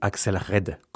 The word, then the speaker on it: Axelle Red: Notre extrait de concert du jour, nous le devons à Axel Red, ou Axel Red quand on prononce bien la française, qui interprète un de ses succès, Je t'attends.